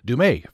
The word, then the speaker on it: Dumais: Am I pronouncing your name correctly? – No, okay. Close. It's Dumais. It's French. – Oh, Dumais. Okay, French. Dumais.